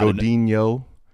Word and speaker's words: Godino: Oh God, God Godino. There's no tilde over the N. – Yeah, because I want to pronounce it like a Spanish name, yeah. Like a Mexican.